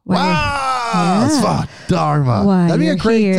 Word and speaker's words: Svadharma: My podcast is my Svadharma. Is that the right word? Did I say that right, Bryn? – No. It's your Svadharma. Svadharma.